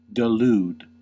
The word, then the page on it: delude: American English